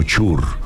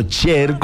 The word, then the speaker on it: Cher: En algún momento la presentaron en un programa de estos famosísimos en la televisión, la presentaron como Chur. – Ay, no, de verdad. – Se equivocó el presentador, dijo Chur. Entonces ya dijo, no, de ahora en adelante me llamo Cher con acento en la E.